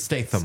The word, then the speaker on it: Statham: Yeah, he's Jason Statham is pixel art. – Yeah, he is. He is the pixel art of a person. – Is it Statham or Statham? – It's Statham, Statham.